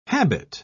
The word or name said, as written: habit